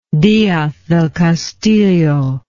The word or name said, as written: diaz del castillo